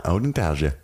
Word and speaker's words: odontalgia: And just like he says, it's spelled, if you want to know, all you spelling bee people, it's spelled O-D-O-N-T-A-L-G-I-A. – Ah, Odin Talgia.